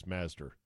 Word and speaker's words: Mazdzer: His name is Chris Mazdzer. That's two Z's. Mazdzer. Although I think it's just pronounced Chris Mazder.